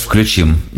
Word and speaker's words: включим: Ты еще все время говоришь «включим», кстати, а вообще же «включим». Не люблю поправлять, но вдруг тебе важно это.